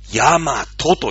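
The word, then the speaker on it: 大和: ね、その和っていう根本原理を持った小さな集団がどんどんどんどん大きくなって、大きな和になったんですね。はい、大きな和と書いて何と読みますかヤマトと読むんですね。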